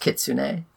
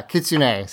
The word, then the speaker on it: kitsune: I do just want to say it is pronounced Kitsune. – Thank you. I knew I probably said it wrong, so I appreciate it. Yeah, Kitsune.